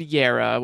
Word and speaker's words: Jera: The word Yara, which is spelled Jera, the J is pronounced kind of like a Y, is this it's this the root of our English word year.